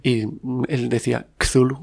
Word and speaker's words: Cthulhu: Chulu, Tulu, Cthulhu... Se supone ajá, que es Cthulhu, porque la...